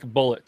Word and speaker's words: Bulleit: It says it is pronounced just like bullet.